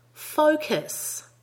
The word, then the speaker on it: focus: In the next word, make sure you get a good O diphthong in the first syllable. The vowel in the second syllable is a schwa. Ready? Focus. And again, focus.